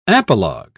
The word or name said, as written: apologue